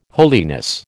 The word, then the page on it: holiness: American English